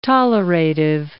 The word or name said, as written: tolerative